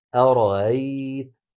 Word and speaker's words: أرأيت: وقلنا بأن التسهيل أن تنطق الهمزة بين الهمزة المحققة وحرف المد المجانس لحركتها، حركتها فتحة ويجانس الفتحة الألف، فتنطق بين الهمزة المحققة والألف هكذا أرأيت أرأيت، صوت بين الهمزة المحققة والهاء وليس بها.